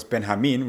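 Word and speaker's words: Benjamín: I can introduce myself as Benjamin, which is the Spanish pronunciation of my name.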